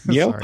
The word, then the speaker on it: Niel: Xavier Niel, if you want to pronounce it the American way. Niel.